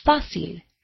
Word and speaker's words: fácil: And the word fácil ends in a consonant other than N or S, but the stress is not on the last syllable, so a written accent mark is needed over the A.